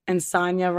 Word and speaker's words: Insogna: But they just said, like, Insogna rhymes with lasagna. And so now I just see lasagna. So I'm like, Insogna.